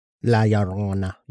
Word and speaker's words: La Llorona: If they believe in aliens, if they believe in Chupacabra, La Llorona. I'm sure I pronounced that wrong. – La Llorona. – La Llorona. Gotta roll my R's.